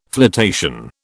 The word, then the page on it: flotation: British English